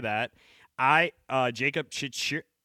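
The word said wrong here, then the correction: Chychrun: It's Chikrin, by the way.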